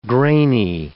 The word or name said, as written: grainy